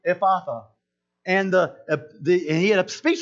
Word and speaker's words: Ephphatha: It's Ephphatha.